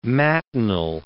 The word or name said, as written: matinal